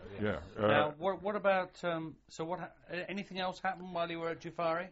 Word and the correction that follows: Jufair: No, not Jufair. – Jufair, sorry.